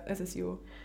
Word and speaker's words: SSIO: SSIO? – Ja, SSIO.